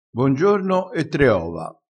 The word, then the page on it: bongiorno: dialettale